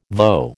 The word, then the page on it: voe: American English